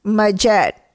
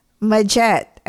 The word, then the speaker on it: Majet: And my dad is just this unique, fabulous artist who came up with the nickname Majette. And if you haven't guessed it, it's a combination of Maria and Janet. Majette. If you can't pronounce it, call me Maria. Please, it's easy. M-A-J-E-T. Majette.